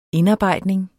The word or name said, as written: indarbejdning